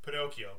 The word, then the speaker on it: Pinocchio: If like now, if you see the word Pinocchio, you know it's pronounced Pinocchio, but you show that to an American audience in 1940, they're probably like, Pinocchio!